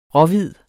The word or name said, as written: råhvid